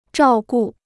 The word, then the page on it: 照顾: zhào gu